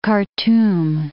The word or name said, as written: Khartoum